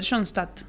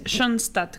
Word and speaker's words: Schoenstatt: Schoenstatt. – ¿Con O o con...? – Es Schoenstatt, que es como una O que tiene diéresis.